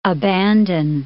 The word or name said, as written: abandon